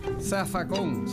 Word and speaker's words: zafacón: Zafacón, se escribe con Z, pero se dice con S en puertorriqueño.